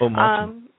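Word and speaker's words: Omartian: This particular one is The Power of a Praying Husband by Stormy, I don't know how to pronounce her last name, O'Martian? – Mm-hmm. O'Martian.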